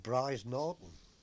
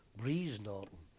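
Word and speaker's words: Brize Norton: We found that we were at a small place in Oxfordshire, a place called Bries Norton, or as the locals down there used to pronounce it, Bries Norton.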